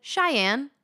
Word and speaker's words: Xynne: I met a child whose name was spelled X-Y-N-N-E. And I didn't even try to pronounce it because I knew I would get it wrong. So I asked the parents, expecting not what they said. I said, oh, what a unique name. How do you say it? And they said, Cheyenne.